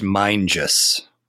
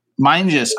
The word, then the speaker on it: Mingus: I think it's pronounced Mingus. – Mingus. Oh, yes. That is right.